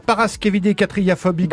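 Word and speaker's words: paraskevidékatriaphobie: Les hôteliers, la parascavidecatriaphobie que vous avez très bien prononcé, Frédéric.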